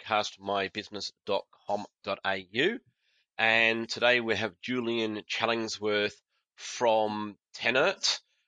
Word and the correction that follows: Tesserent: or Tesserent, sorry, Tesserent. Tesserent, there we go.